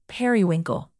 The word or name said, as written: periwinkle